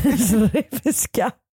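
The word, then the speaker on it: Srbská: Srebiska, kanske. Det uttalas lite så här, eller så här stavas det. S-R-B-S-K-A.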